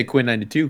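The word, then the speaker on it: Quinn XCII: You could just say Quinn 92 because XCII is 92 in Roman numerals.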